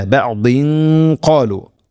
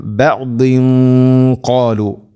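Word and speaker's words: ബഅദിൻ ഖാലു (بَعْدِ قَالُوا): അതുഹദ്ദിസൂനഹും, ഇനി ഇവിടെ ഇല ബഅദ് എന്നുള്ള അത് കൂട്ടി ഒതുകയാണെങ്കിലോ, ഇല ബഅദിൻ ഖാലു, ഇഖ്ഫാം ബിഗുന്ന, അവ്യക്തമാക്കി മണിക്കണം. തൻവീനു ശേഷം ഖാഫ്, ഖാഫ് ഹെവി ലെറ്ററാണ്, അതോടുകൂടെ അതിൻ്റെ കൂടെ വരുന്ന ഗുന്നയും ഹെവി ആയിരിക്കണം. ബഅദിൻ ഖാലു ഇങ്ങനെയാവണം.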